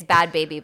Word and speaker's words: Bhad Bhabie: Okay, it's Bhad Baby, but it's spelled Bhad Bhabie. – How's it spelled? – B-H-A-D-B-H-A-B-I-E. – Bhad Bhabie.